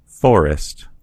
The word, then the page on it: forest: American English